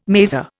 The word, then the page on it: maser: Indian